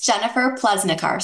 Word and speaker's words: Jennifer Plesnicar: It's Jennifer Plesnicar.